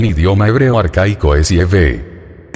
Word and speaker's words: Yahvé: Por eso es que en este librito y en el resto de libros míos la pronunciación que utilizo siempre es Yahvé, en hebreo arcaico, para referirme al nombre de estos dos dioses eternos, el Padre y el Hijo.